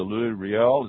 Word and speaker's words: Louis Riel: and there we got into the Louis Riel. Is that the way you pronounce it? – Yes.